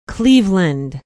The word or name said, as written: Cleveland